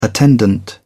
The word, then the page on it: attendant: British English